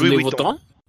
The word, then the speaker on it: Louis Vuitton: Así como otra nota que, que vi que alguna, un aspecto de un campeón del juego lo diseñó Luis Vuitton, o no sé cómo se pronuncia. ¿Es Louis, Vuitton? – Ah.